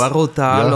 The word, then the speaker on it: Douro: Und dann im Norden oben das Gebiet vom Portwein, das, wie sagst du, Douro, wie spricht man das aus? – Douro-Tal, und gleich dahinter weiter im Norden dann die Granitberge vom Peneda-Xerez-Nationalpark, auch ein ganz besonderes Wanderjuwel noch.